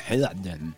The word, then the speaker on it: Helden: Este temazo en alemán se pronuncia Helden.